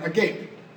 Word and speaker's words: agape: So there's this Greek word, it's spelled A-G-A-P-E, it's pronounced agape.